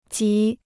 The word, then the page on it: 极: jí